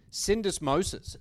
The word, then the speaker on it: syndesmosis: Syndesmosis.